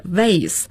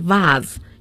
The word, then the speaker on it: vase: 那么刚才周老师的读音是 vases, 那么还有一个发音是 vase, 一个是 vase, 一个是 vase, 英音 vase, 美音 vase。